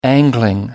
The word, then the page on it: angling: American English